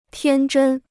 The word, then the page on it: 天真: tiān zhēn